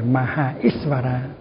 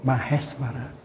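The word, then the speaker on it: Maheśvara: Nhưng mà chữ E, chữ A nó gặp chữ Y, chữ A nó gặp chữ Y, nó thành là chữ E. Cho nên đáng lý phải gọi Maha Isvara thì phải đọc là Maheshvara.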